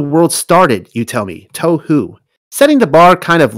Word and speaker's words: tohu: You pronounce it like this, tohu. Try it, tohu. Again, tohu. Practice makes perfect.